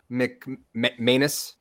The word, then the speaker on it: Manus: And like, I have to say this, but like, I looked up Bishop Manus. I think it's pronounced like anus, right?